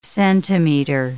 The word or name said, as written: centimeter